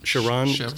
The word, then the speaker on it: Charon: It's like Charon or Charon. – Charon? Is it? – Charon. C-H-A-R-O-N, Charon.